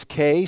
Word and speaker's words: Kia: It's Kay, spelled K-I-A, but she pronounces it K.